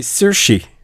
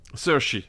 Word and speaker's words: Saoirse: Oui, c'est avec Saoirse Ronan et Timothée Chalamet, mais voilà, je vois l'affiche, mais je ne l'ai pas vu. – Alors, c'est Saoirse. – Saoirse, ok.